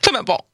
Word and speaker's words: Clément Beaune: Clément Beaune.